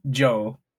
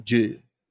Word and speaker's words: Jou: And Ju, J-O-U. – Oh, that's Joe. – Yeah, that would be Joe. That would be Joe. – No, that actually is pronounced Joe. – Joe. It's not Ju. – Not Ju, it's Joe.